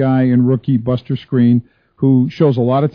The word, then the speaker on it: Skrine: And it's funny, you know, I learned to always pronounce Skrine from listening to your show. But I know that it's phonetically it's Skrine, and Maybe this is a rookie initiation, but one of the coaches the other day said, "Hey, Skrine."